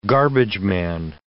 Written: garbageman

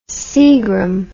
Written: Seagram

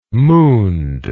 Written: mooned

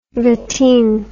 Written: Wettin

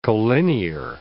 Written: collinear

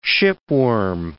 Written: shipworm

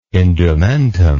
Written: indumentum

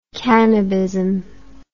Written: cannabism

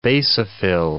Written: basophil

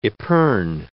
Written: epergne

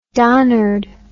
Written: donnered

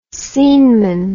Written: sceneman

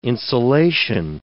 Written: insulation

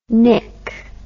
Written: knick

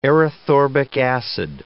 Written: erythorbic acid

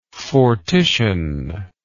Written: fortition

